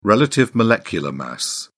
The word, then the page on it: relative molecular mass: British English